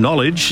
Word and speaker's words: knowledge: The silent letter in knowledge is the first one, K.